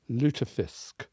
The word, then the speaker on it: lutefisk: Jacques, Kat Jarman, as representative of your people, lutefisk.